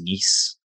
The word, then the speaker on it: Nice: Yeah, I'll probably butcher this, but I believe it was Nice. I think that's how you say it.